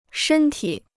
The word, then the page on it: 身体: shēn tǐ